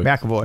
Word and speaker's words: McAvoy: McAvoy.